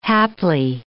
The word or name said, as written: haply